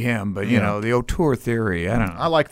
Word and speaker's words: auteur: 1935, the director, screenwriter, actor, a true auteur. – Yeah. – Am I saying that right? – Auteur, yeah. – Auteur. – That's correct.